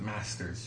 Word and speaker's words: Maester: Maesters are cool. One, did I pronounce that right? – I guess, yeah. – M-A-E-S-T-R-S? – I've always internally pronounced it Masters, just for... Maester feels kind of awkward.